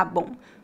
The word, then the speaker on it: bom: Nós pronunciamos a letra M nas palavras bem e bom? Não exatamente. A letra M nas palavras bem e bom tem a função de apenas nasalizar o som das vogais. A letra M não é pronunciada claramente como um M.